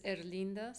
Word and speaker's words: Herlinda: My name is Erlinda Zamora, H-E-R-L-I-N-D-A, Zamora, Z-A-M-O-R-A.